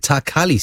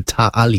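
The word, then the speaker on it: Ta' Qali: I was going to pronounce it Takali Stadium, but big shout out to Claire, who's lived in Malta in the past. And the queue in Maltese is silent, so it's the Ta'ali Stadium.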